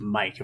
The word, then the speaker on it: Maick: It is pronounced Mike, if I remember correctly, right? – Yeah, it might be Mike or Mike, but I think it is Mike. And it's spelled M-A-I-C-K.